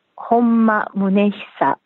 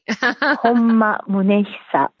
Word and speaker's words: Honma Munehisa: And I actually spoke with a Japanese friend yesterday to get the pronunciation of Honma Munehisa. – Oh my gosh, well, you're one up on me. – Honma Munehisa.